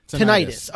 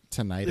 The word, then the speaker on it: tinnitus: The doctor pronounces it tinnitus, and that's why I was like, "What the fuck is that?" But if the useless drug commercials that I've seen have taught me anything, it's pronounced tinnitus.